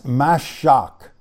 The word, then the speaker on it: mashach: The first one I want to talk about, and there's two different ways to spell it, but it's mashach, M-A-S-H-A-C-H.